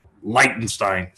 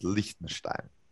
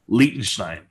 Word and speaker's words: Liechtenstein: Whether it's in Croatia, whether it's in Barcelona, whether it's in Liechtenstein, I don't know. I mean, anywhere is taking place. – Another pronunciation correction. It's Liechtenstein. – Liechtenstein.